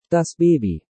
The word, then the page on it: das Baby: German